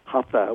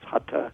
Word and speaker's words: hatha: And this is usually under the category called hatha, and most of us mispronounce it, hatha. We've Americanized it. It's hatha yoga.